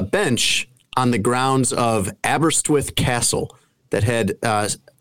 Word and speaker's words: Aberystwyth: Uh, I believe it is called Aberystwyth, Aberystwyth, Wales.